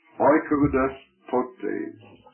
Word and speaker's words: oikodespotes: The owner, and in parentheses, put this Greek word, oikoudos potes, O-I-K-O-D-E-S-P-O-T-E-S, and that's a long E, put a line over that last E, oikoudos potes. What does this mean? Well, look at the word. Oikos is a house. D-E-S-P-O-T, what's that?